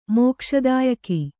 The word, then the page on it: ಮೋಕ್ಷದಾಯಕ: mōkṣa dāyaka